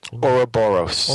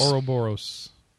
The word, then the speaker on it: Ouroboros: to get Ouroboros. – Ouroboros.